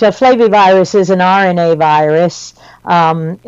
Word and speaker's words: flavivirus: Now, being based in South Florida, those in South Florida are familiar with something called a flavivirus, if I pronounced that correctly, and that would correct.